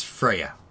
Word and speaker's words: Freya: Yes, Freya. – Yes. – If I pronounce that correctly. – Yes, you did.